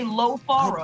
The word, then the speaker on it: LoFaro: But I'm saying, specifically pronounce the O. Say LoFaro. – I do. – And they'll get it.